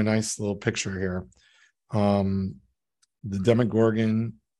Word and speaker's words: Demogorgon: Do you know about the demigorgons? Demigorgon. – I know what a demigorgon is. I have not been watching Stranger Things. It's not, our television doesn't get that, as we say. – Demogorgon.